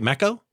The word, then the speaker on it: Meco: Did I pronounce it right? Mecco? – Yeah, M-E-C-O, yep.